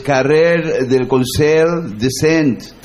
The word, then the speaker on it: Carrer del Consell de Cent: Es Carrer del Consell de Cent, 338. – Impecable pronunciación catalana.